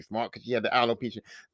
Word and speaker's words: alopecia: Alicia. I ain't say that right. She had the alopecia. It's called alopecia.